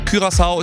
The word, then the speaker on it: Curaçao: Und wir haben schon mal rausgefunden, es heißt Curaçao, nicht Curaçao. – Richtig. – Auch wenn das der ein oder andere vielleicht denken mag, Curaçao ist die richtige Aussprache.